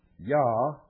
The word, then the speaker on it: Jah: Yah, J-A-H, the first syllable, represents the name Yahweh, or Jehovah, the name of the God of Abraham, Isaac, and Jacob of the Bible.